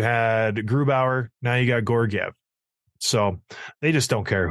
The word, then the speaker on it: Georgiev: It'll be interesting to see how Georgiev, is that how you pronounce it? – Sure. Georgiev.